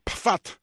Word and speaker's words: Pfaadt: Se llama Brandon Pfaadt. Se escribe P-F-A-A-D-T. ¿Ok? Brandon Pfaadt.